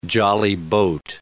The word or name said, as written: jolly boat